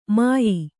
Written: ಮುಯ್ಯೀ

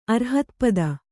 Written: ಅರ್ಹತ್ಪದ

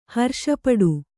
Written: ಹರ್ಷಪಡು

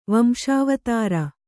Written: ವಂಶಾವತಾರ

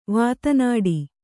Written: ವಾತನಾಡಿ

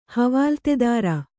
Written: ಹವಾಲ್ತೆದಾರ

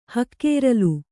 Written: ಹಕ್ಕೆರಲು

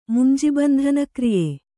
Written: ಮುಂಜಿಬಂಧನಕ್ರಿಯೆ

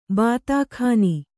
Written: ಬಾತಾಖಾನಿ

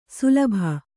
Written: ಶಲಭ

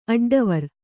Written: ಅಡ್ಡವರಿ

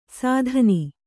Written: ಸಾಧನಿ

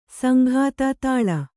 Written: ಸಂಘಾತ ತಾಳ

